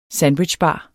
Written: sandwichbar

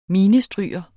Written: minestryger

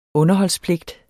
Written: underholdspligt

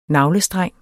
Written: NAVLESTRENG

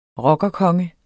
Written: rockerkonge

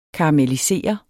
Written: KARAMELLISERET

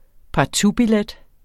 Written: partoutbillet

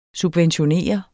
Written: subventionere

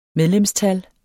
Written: medlemstal

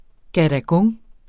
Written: gadagung

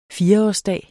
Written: fireårsdag

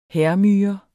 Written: hærmyre

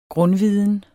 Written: grundviden

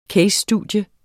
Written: CASE

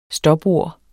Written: stopord

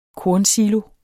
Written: kornsilo

